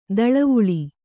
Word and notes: ದಳವುಳ: daḷavuḷa

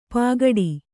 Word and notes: ಪಗಡಿ: Kannada

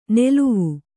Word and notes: ನೆಲುವು: neluvu

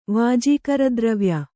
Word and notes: ವಾಜೀಕರದ್ರವ್ಯ: kannada

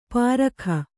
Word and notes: ಪಾರಖ: pārakha

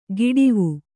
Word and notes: ಗಿಡಿವು: Kannada

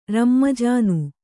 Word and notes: ರಮ್ಮಜಾನು: rammajānu

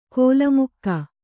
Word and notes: ಕೋಲಮುಕ್ಕ: kōlamukka

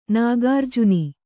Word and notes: ನಾಗಾರ್ಜುನಿ: Kannada

